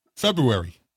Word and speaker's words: February: I'm telling you, all you motherfuckers that say February, you're saying it wrong. Look at the way it's spelled. But coffee. I think it's like this majority thing. Everybody says February, so it's got to be right. No. Look at the way it's spelled. There's no silent R in there.